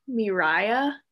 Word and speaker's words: Miiriya: And then there's this app called, I think it's Miraya. So it's spelled Miiriya.